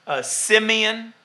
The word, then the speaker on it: Simeon: So Simon was his name in Greek. Simeon was how they would pronounce it in Hebrew.